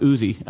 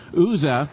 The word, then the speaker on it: Uzzah: We know that the ark is on a cart, and we know that cart hits a Philadelphia-sized pothole, and Uzzah, and I think the East Coast, you guys pronounce it Uzi, but Uzzah reaches out.